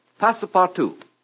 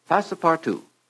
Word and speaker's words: Passepartout: Passepartout. Passepartout. P-A-S-S-E-P-A-R-T-O-U-T. Mr. Kieran, my spelling is correct. – Spelling is correct and pronunciation is beautiful.